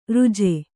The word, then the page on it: ರುಜೆ: ruje